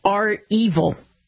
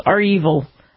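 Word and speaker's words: REvil: And it's R-Evil. It's R-Evil. That's how you say it.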